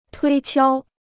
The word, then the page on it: 推敲: tuī qiāo